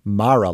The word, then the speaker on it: Mara: Importantly, to the specific discussion here, Rooney Mara does in fact break from the family and chooses to pronounce her name as Mara, like tiara, which also happens to be closer to the traditional pronunciation of that Irish surname.